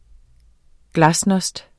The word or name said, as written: glasnost